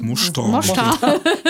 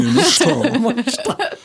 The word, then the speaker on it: Mostar: Aus Mostar. – Das ist das Balkan, die balkandeutsche Aussprache. – Ich trinke ein bisschen Mostringe, Mostar.